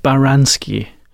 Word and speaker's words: Baranski: Yes, my name is Mark Baranski. – Could you spell that, please? – Mark, M-A-R-K, Baranski, B-A-R-A-N-S-K-I.